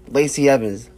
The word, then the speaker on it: Lacey Evans: My choices were, um, same as you, um, Aleister Black, and my the choice of the women's was, um, Lacey Evans, um, Les- Lacey Evans. – Yeah. – I think I yeah. said that right. – No, no, you got it.